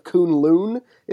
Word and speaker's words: Kunlun: One, it shows like how Iron Fist got to the mystical land of Kunlun, is how it's pronounced.